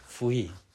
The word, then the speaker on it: fui: Ja. Fui. F-U-I. Fui. Da, da, na, da Betonung ist auf dem I.